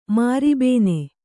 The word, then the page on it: ಮಾರಿಬೇನೆ: Kannada